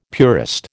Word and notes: purist: American English

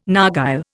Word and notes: narghile: Indian

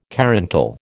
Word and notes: caruncle: American English

